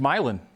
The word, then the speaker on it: Milan: It's Milan. – Okay. – You pronounce it Milan, like the one in Indiana that I think Hoosiers comes from.